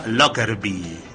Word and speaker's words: Lockerbie: Y bueno, que se estrella en un pequeño poblado escocés que se llama Lockerbie.